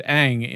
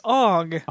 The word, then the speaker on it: Aang: By the way, guys, if you don't know, he played Aang in The Last Airbender. – Only it wasn't pronounced that way in the movie. It was Aang, which probably was like the last nail in the gasket.